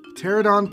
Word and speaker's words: Pteranodon: Pteranodon Pond. – The P is silent, right? The P is silent? Is that right? Yeah.